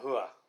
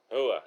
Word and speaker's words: Rua: Hua. – Hua. It's an H, but it's Rua. – Yeah. – But we'll say Hua because he's from Brazil.